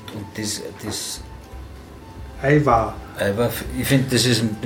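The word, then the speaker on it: Ajvar: Eiwar.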